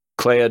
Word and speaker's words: Clea: So we had Clea, is it pronounced? – Clea Duvall, yeah.